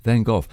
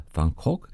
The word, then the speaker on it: Van Gogh: And that's from the Dutch post-impressionist painter Vincent van Gogh.